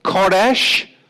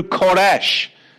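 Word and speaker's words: Koresh (כורש): Remember King Koresh, Cyrus, transliterated as Cyrus, but in the Hebrew, Koresh. Remember King Koresh, spelt with a kaf, resh, sheen.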